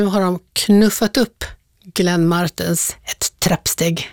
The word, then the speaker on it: Martens: Just det, men nu får vi ta det från början, Glenn Martens med betoning på R-et. Vem är det? – Ja, det är inte Glenn Martin utan Glenn Martens.